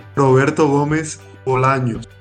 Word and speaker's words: Roberto Gómez Bolaños: Roberto Gómez Bolaños.